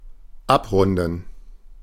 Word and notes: завершать: /zəvʲɪrˈʂatʲ/

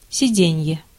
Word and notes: сиденье: /sʲɪˈdʲenʲjə/

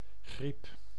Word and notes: griep: [ɣɾiːp]; /ɣrip/; /ɣɾi:p/

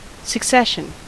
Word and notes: succession: /səkˈsɛʃ.ən/

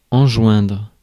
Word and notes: enjoindre: France; [ɑ̃.ʒwɛ̃dʁ]

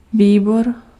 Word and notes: comité: France; /kɔ.mi.te/

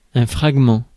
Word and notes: fragment: /fʁaɡ.mɑ̃/

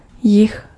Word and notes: jih: /jɪx/

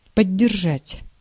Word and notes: maintain: /meɪnˈteɪn/

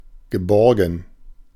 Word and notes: geborgen: [ɡəˈbɔʁɡən]

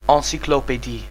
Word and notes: encyclopédie: IPA: /ɑ̃.si.klɔ.pe.di/; French

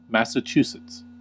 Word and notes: Massachusetts: [ˌmæs.ə.ˈtʃu.sɨts]; /ˌmæsəˈtʃuːsɪts/

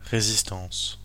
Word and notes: résistance: [ʁe.zis.tɑ̃s]

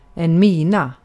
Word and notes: mina: ˈmiː.na; ˈmiːˌna

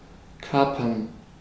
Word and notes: seize: /siːz/

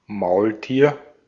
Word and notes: mulet: [my.lɛ]; France

